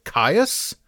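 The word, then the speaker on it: Kyuss: Also known as the worm that walks, spelt K-Y-U-S-S. I always pronounced it Caius, and I believe that's how you pronounce the band.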